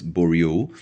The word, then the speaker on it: Bourriaud: You can look this up, give it a brave, which are self-professed emancipatory works as theorized by Nicolas Bourriaud. If I said his name right, Nicolas, B-O-U-R-R-I-A-U-D-S, Bourriaud, I think, in his book Relational Aesthetics.